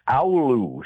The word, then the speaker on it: Oulu: From Finland, Oulu. Someone running from Oulu, Finland.